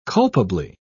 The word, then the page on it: culpably: British English